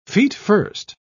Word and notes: feetfirst: British English

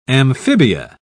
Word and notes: amphibia: British English

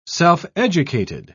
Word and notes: self-educated: British English

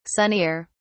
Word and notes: sunnier: British English

